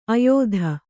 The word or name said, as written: ಆಯತಿ